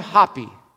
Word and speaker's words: Hapi: Then you have Hapi. Hapi, Happy, whatever you want to pronounce it. It's H-A-P-I.